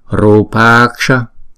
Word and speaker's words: Rupaksha: Hai chữ A này chúng ta sẽ ghép lại thành một chữ A dài. Thì chúng ta đọc là Rupaksha.